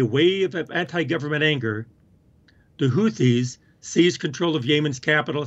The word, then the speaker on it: Houthis: These were the Houthis. Did I say that right? – I think it's Houthis.